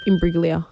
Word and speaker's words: Imbruglia: Imbruglia.